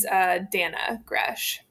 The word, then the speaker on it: Dana Gresh: People in the comments were asking, how do we pronounce this name? Is this Dana, Dina? It is Dana Gresh.